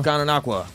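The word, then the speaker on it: Gananoque: How do I say this word? Gananoqua? – Gananoqua. – Is that what it is? – Yeah. – Are you sure? – Yeah. – I think it's Gononoke.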